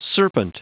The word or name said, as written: serpent